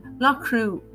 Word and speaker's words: Loughcrew: Loughcrew, in English, is spelled L-O-U-G-H-C-R-E-W, Loughcrew, as in the crew of a ship, for example.